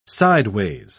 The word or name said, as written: sideways